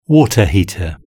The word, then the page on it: water heater: British English